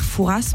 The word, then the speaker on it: Fouras: Comment vous prononcez son nom à ce monsieur? – Ouais, ça, je crois que je sais. Je ne suis pas sûr, mais je crois qu'on dit le père Fouras. – Exactement.